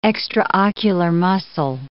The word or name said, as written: extraocular muscle